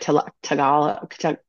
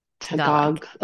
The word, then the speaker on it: Tagalog: Tagalog. – Tagalog. Oh gosh, Tagalog. I always want to put like an O on the end.